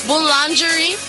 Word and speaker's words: boulangerie: Boulangerie.